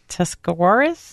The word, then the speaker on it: Tuscarawas: So, Father Davis, after junior high, you attended New Philadelphia, and help me with the pronunciation of this, Tuscarawas? – Yep, that sounds pretty good.